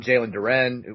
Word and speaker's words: Jalen Duren: And if you're saying, you know, we're going all in on Jalen Duren, which is how recruiting people tell me his name is pronounced, the five-star other big man who's coming out of – like, that's – the stupidest thing I've ever heard of.